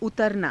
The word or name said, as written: utaranaa